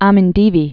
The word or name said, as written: Amindivi Islands